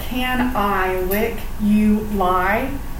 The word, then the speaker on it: canaliculi: So the word canaliculi sounds like can I lick you lie? – Okay, I know that's a really weird pronunciation, but when you look at the word, it doesn't really tell you how to pronounce it.